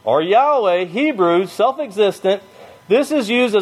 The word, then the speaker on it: Yahweh: Then lower it says, the strong probability is, get it there, the strong probability is that the name Jehovah was anciently pronounced Yahweh.